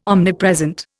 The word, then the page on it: omnipresent: Indian